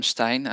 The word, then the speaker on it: Stijn: Well, hi, I'm Stijn.